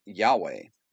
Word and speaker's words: Yahweh: He was to tell the Egyptians that I Am had sent him, which is where we get the name that is spelled YHWH and is pronounced Yahweh.